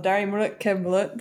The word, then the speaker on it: Dari Mulut ke Mulut: So you write a publication called Darimrut Kemlut. Did I say that correctly? – Pretty close, yeah.